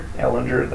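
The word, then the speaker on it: Ehlinger: Edis, is that how you say his name? – Ellinger, that's... I wasn't even close.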